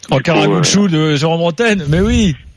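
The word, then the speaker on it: Ankaragücü: En Karagücü. – En Karagücü. – Gücü. – C'est des U, voilà. Ouais. Et le C se prononce DJ.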